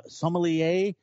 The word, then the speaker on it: sommelier: He's that sommelier. Am I saying that right? From Xanterra Glacier Lier. Park? – Sommelier. So sommelier comes from French. – Sommelier. Yes, it does.